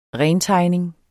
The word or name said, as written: rentegning